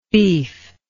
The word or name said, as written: beefless